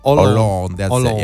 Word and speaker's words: Hollande: Yeah, I don't really know how to say the president's name. François Hollande. – Hollande. – Yeah, you're right.